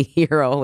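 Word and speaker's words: Herro: But either way, you said that you thought, oh, for sure everyone's going to know how to pronounce my last name now, Hero. But it's actually Hero when it comes to Tyler.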